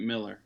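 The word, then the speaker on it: Millar: I think he pronounces it Miller, but yeah, the spelling is a little confounding.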